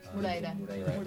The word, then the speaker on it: Moreira: and exercise and, and other authors as well, such as um, again, I'm going to pronounce this horribly, but Morera, Morera Moreira.